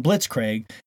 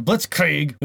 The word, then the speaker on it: Blitzkrieg: And they were joking because I called them Blitzkrieg instead of Blitzkrieg, which I guess is the proper pronunciation, is German, of course.